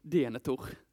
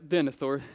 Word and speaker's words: Denethor: Jag hörde sånt här seminarium där det var någon som alltid uttalade allting, allting på svenska så här. Alltså det lät också riktigt fattigt. Denethor. Frodo Säcker och så vidare. Nej, Denethor säger jag.